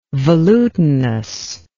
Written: velutinous

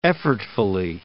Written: effortfully

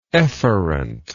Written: efferently

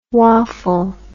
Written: woffle

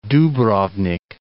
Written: Dubrovnik

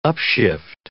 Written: upshift

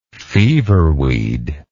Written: feverweed

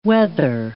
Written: weather

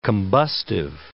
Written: combustive

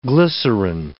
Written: glycerine

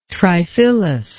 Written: triphyllous

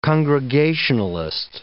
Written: congregationalist

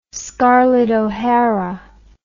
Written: scarlett o'hara

